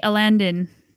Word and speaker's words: Alandon: So, Mountain by MTB Alandon. – You read it right. Oh, he's a regular. He's a regular. We debated for like an entire episode how you pronounced it. – It's like Aladdin, but he's yeah.